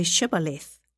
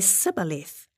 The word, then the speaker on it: Shibboleth: If the man said, "No, I'm not," they would tell him to say Shibboleth. If he was from Ephraim, he would say Sibboleth, because people from Ephraim cannot pronounce the word correctly.